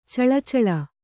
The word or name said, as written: ಚಲ್ಲುಚಾಲು